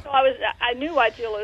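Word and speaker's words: Weigela: A lot of people say azalea, wisteria, magnolia, hydrangea, but this is Wygella, and ain't no ea on the end.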